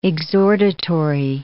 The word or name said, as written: exhortatory